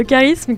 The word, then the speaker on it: charisme: Le charisme qui s'écrit donc CH, mais qui se prononce que, on peut se demander pourquoi.